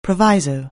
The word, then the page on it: proviso: anglais